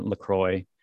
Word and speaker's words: LaCroix: But yeah, it's they don't endeavor to adopt the French pronunciation for the actual locale after which the drink is named. So it's fine to just call it LaCroix.